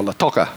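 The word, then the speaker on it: Lautoka: We'll be constructing a house, or possibly two, in the Lovu settlement in partnership with C3 Latoka, or Latoka, as it's correctly pronounced.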